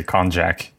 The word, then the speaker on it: Konjac: Was that a soft J? – Actually, that is the way I say it to people when I actually meet them. I say Konjac, just so.